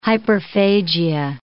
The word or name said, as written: hyperphagia